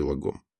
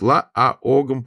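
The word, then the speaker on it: lagom: В шведском языке есть звук, средний между а и о. Именно он является ударным в слове лагом. Это долгий звук ла-а-огом, попробуйте произнести правильно.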